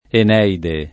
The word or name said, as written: Eneide